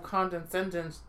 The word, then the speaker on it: condescension: There is almost a touch of condescension. Tell that. Is it? Am I pronouncing that right? – Condescendence? – Condescension. – Condescension.